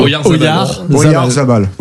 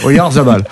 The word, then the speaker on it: Oyarzabal: Oyarzabal. – Voilà, Oyarzabal.